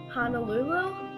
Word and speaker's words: Honolulu: Okay, so it's illegal to annoy birds while visiting any public park in Honolulu.